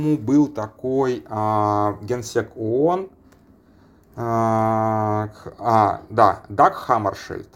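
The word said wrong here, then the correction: Хаммаршёльд: Вот, сейчас я буквально... Хаммершольд.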